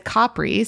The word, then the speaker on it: Capri: You mentioned Capri, but you said Capri, so you're taking on the pronunciation.